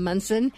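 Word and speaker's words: Monson: Congratulations, I am Kim Munson.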